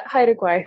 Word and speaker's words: Haida Gwaii: And then you were also, we almost met up in person because you were in Haida Gwaii. That's how you say it, right? – Yeah, Haida Gwaii.